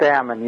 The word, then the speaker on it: Ammon: Yeah, it's Ammon. You said it right.